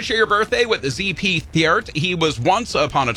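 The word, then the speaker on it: Theart: ZP, just the two initials, and Thiert.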